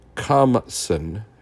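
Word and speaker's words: khamsin: Sometimes the name Qamism, let me make sure I'm saying that right. Qamsin, Qamsin, Qamsin, Qamsin is applied to them.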